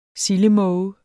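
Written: sildemåge

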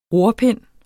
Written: rorpind

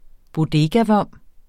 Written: bodegavom

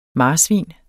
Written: marsvin

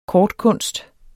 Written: kortkunst

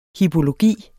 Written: hippologi

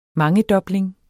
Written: mangedobling

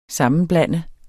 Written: SAMMENBLANDEDES

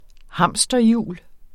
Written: hamsterhjul

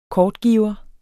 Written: kortgiver

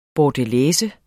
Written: bordelaise

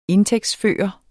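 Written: INDTÆGTSFØRE

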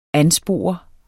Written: anspore

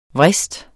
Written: VRISTEN

